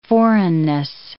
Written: foreignness

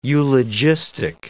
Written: eulogistic

